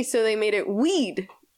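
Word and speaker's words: oui: You don't pronounce the O. Like when you say weed, like if weed, that's what you're saying.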